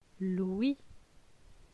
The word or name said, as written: L'ouie